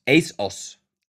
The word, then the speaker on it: ASOS: Man spricht's ASOS aus. ASOS. – ASOS.